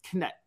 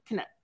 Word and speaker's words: Knecht: So on Friday, just as we wrapped up our show and got ready for the weekend, I believe the name is pronounced Knett, Dalton Knett from Northern Colorado, or maybe it's just Dalton Knett. K-N-E-C-H-T.